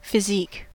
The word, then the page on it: physique: American English